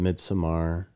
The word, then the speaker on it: Midsommar: I call it Midsommar. I think that's what the director said. I read an interview that said that it's supposed that's to be how you pronounce it in Swedish.